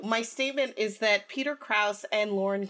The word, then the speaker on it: Krause: It's Krause. If you listen to Armchair Expert, he is on Armchair Expert with Dax Shepard, who plays Crosby, and he pronounces his name Peter Krause.